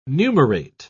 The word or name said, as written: numerate